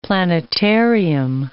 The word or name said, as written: planetarium